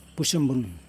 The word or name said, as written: pusumbun